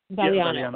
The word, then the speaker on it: Valignano: His name is Alessandro, I think, Valignano. Am I pronouncing it correctly? – Valignano.